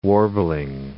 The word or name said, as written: warbling